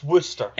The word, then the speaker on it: Worcester: Long arguments about how you pronounce Worcester. It's Worcester.